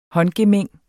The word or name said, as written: håndgemæng